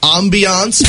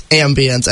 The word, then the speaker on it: ambience: Okay, for Kyle, I'm going to need you to stop saying ambiance because you're not highbrow. And actually pronounce it correctly, it's ambience. Every time Kyle on that, though.